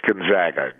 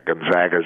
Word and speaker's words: Gonzaga: It's Gonzaga, Gonzaga Zags.